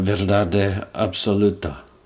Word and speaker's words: verdade absoluta: was the Autoramas, and how do you say it? Uh, verdade absoluta. That sounds good.